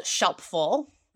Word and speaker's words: Shelpful: And so I started this company called Shelpful. Rhymes with helpful, has an S in front.